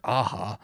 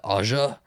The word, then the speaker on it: Aja: It is directed by Alexander Aja. I didn't know how to say his last name. I've been saying it my whole life, Aja. And I guess it's Aja who he wrote and directed it.